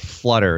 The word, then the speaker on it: Phluttr: But it's set in present-day San Francisco, and it's the tale of this really diabolical social media company called Flutter, and it's spelled P-H-L-U-T-T-R because we know how to spell in Silicon Valley, right? – Yes.